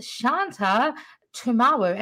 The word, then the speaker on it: Shanta Tumawu: So today, I am so happy to be joined all the way from Colorado, Ms. Shanta Tumawu.